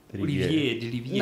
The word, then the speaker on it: Olivier de Rivière: Není to Olivier de Rivière? – Olivier de Rivière. – Ne, Olivier de Rivière. Když už si tady budeme opravovat výslovnosti.